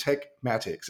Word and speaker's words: Tekmatix: It's called Tekmatics. It's spelled T-E-K-M-A-T-I-X. It is the best tool I have ever used.